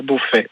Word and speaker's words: Beaufey: Sinon, c'est à mon nom, Jean-Pierre Beaufait. – Ah, Beaufait. Alors, ça s'écrit comment, Beaufait ? – B-E-A-U-F-E-Y. – B-E-A-U-F-E-Y, Jean-Pierre Beaufait, à Uzès.